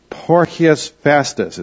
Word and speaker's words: Porcius Festus: This man, Porcius Festus, is how you would say it. They pronounce the C's hard C's.